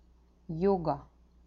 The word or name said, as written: yoga